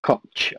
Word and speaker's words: Koch: So Ben White's a big loss in the defence, but we have signed Robin Koch. I think you pronounce his surname, but you know you can mispronounce it hilariously if you like. But I think it's pronounced Koch, and he hopefully will be a direct like-for-like replacement for White.